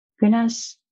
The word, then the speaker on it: gunas: I didn't pronounce that correctly. Gunas? – Yeah.